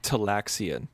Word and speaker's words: Talaxian: It's pronounced Talaxian.